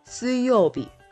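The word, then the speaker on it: suyobi (水曜日): Sui can be used in suyobi, which means Wednesday, and mizu can be used in nomu mizu, which is potable water or drinkable water.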